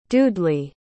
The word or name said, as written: dudely